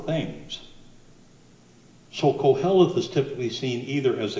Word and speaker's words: Koheleth: That's pronounced Koheleth, Koheleth.